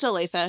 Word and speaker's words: Olathe: Yeah, it's pronounced Olathe, if you're curious.